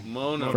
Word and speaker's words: Mono: But has come down from Mono. Did I say it right? Mono. – Mono.